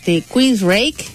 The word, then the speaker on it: Queensrÿche: Es de la banda, se pronuncia así, Queensrÿche.